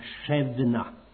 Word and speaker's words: Shebna: Men många gånger är ju S-en ett sje-ljud och så är fallet här. Och när det står B är det ganska ofta liktydigt med att uttalas som V. Och han heter alltså egentligen Shebna.